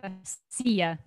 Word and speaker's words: Anastasiia: Anastasia. Anastasia. – Anastasia. – Oh, Anastasia. – Yeah, that's why there's two eyes, right? – Exactly.